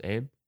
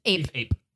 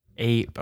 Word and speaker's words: Ape: Is it pronounced Abe? – Ape. – Ape. Okay.